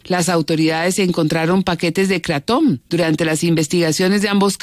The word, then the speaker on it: kratom: Es kratom, así se llama. Kratom en inglés se pronunciará kratom.